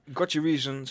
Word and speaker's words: Got Your Reason: Eh, Got Your Reason, zeg ik dat goed? – Ja, Got Your Reason hier op Hit Radio RTV Meppel.